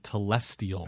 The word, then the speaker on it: telestial: I think I said it right. – Yes, I think you did. Celestial, terrestrial, and telestial.